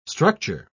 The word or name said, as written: structure